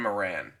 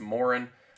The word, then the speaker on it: Moran: Next, our third position player, Colin Moran. And thankfully, I did not mispronounce that one as Morin, because before this call, you said his name, and I remembered it.